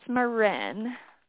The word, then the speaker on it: Marin: It's Marin. – Can you spell that, please? – Sure, M-A-R-I-N.